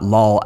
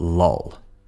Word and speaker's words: lull: Not lull as in laugh out loud, but lull. Lull and lull. Slight difference there. Be careful with that vowel sound, all right?